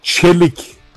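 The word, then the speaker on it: Çelik: ho detto giusto? Non da essere ovviamente titolare della Juventus. Questo si dice Celik. – Vedete il mio amico e collega turco, Celik.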